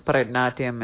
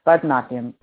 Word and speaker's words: Bharatanatyam: And I wasn't even in Bharatanatyam. Am I even saying that right? – Bharatanatyam. – Wow, that wasn't even close.